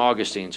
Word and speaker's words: Augustine: We still quote Augustine. Some pronounce him Augustine, so just, you know, it's the same person.